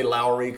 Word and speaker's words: Lauri: I say Lowry because I think that's how they pronounce it in Finland.